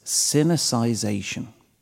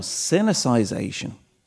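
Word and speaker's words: Sinicisation: The word is cynicization. Right. Now, cynicization.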